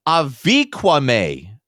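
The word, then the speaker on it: Avi Kwa Ame: the Havanequa May. I'm having trouble. Thank you. I got it. – Havanequa? Havanequa? Like Cuba's Havana? Havanequa? It's spelled A-V-I-K-W-A. How's that Havanequa? It's Aviquame. Aviquame. Not Havanequa.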